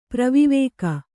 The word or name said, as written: ಪ್ರವಿವೇಕ